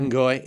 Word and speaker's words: Ngoi: Oh, two syllables. – Yeah. – Because I know some people are just enjoying Ngoi. – I know some people are ignoring the N completely. – A lot of times the N is silent.